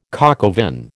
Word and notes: coq au vin: American English

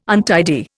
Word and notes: untidy: Indian

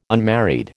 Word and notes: unmarried: American English

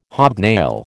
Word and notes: hobnail: American English

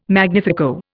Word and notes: magnifico: Indian

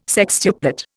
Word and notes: sextuplet: Indian

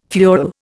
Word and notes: fuel: Indian

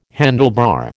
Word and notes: handlebar: American English

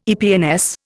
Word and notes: EPNS: Indian

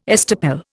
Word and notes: estoppel: Indian

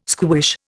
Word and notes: squish: Indian

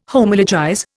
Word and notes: Homologize: Indian

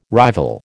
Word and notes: rival: American English

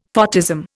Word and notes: photism: Indian

